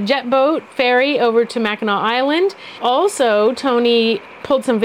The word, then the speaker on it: Mackinac: And one of the days, 8 maybe, I don't remember exactly, we took a boat and went over to Mackinac Island. Sorry, I almost said Mackinac, which is how it's spelled.